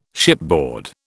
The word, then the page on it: shipboard: British English